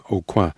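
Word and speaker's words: Aucoin: The drummer Michael Brothers, the pianist Andy Navala, and the bass player Tim au Coin. I'm pronouncing that in the French way because he's a New Orleans native, although he lives elsewhere now.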